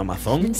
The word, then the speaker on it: Amazon: Bueno, salvando que como dice Ataúl fatila Morales, no se dice Amazon sino Amazon.